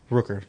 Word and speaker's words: Rooker: Starring Chris Pratt, Zoe Saldana, Dave Bautista, Vin Diesel, Bradley Cooper, Michael Rocca, Rooker. I can't say that wrong.